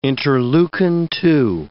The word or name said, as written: interleukin-2